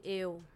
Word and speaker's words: eo: tlogela phone eno. Tlogela phone eno. Am I saying it right? – Tlogela phone eo. – Eo.